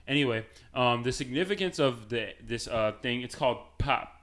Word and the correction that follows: Pauli: Oh, gosh. I can't pronounce things. The Pauli net, it's called, because the Pauli exclusion principle basically says that two electrons can't exist in the same state within an atom.